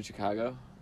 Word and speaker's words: Chicago: Oh, it was in Chicago? – Yeah. – Mm-hmm. – Chicago! Is that how the locals say it? – Exactly.